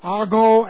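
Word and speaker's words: ago (ἄγω): It comes from the prepositional prefix pros, which means face to face with, and then ago, A-G long O, to lead, to go, to bring face to face, have access, to approach, being permitted into the presence of someone.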